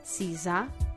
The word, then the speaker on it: Siza: Questo complesso architettonico, che è invece adibito per il negozio monomarca di Elisabetta Franchi, che è stato fatto da Alvaro Siza, spero che la pronuncia sia giusta. – Siza. – S-I-Z-A. – La Z non si dice Z come da noi, è Siza.